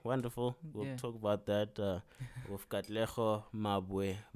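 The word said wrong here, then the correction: Katlego Maboe: Katlego Mabwe.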